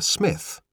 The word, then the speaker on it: Psmith: It's Thorpe and Briscoe's Coal Merchants, because it's where Smith, you may know that it's spelled P-S-M-I-T-H, the P is silent, that Smith sees a beautiful girl sheltering from a downpour underneath the awning, underneath the canopy of the coal merchants.